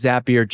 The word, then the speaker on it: Zapier: They all chose Zapier just like us to streamline their work, save money, and find more time for what matters most. That's the reason Zapier rhymes with happier.